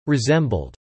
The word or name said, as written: resembled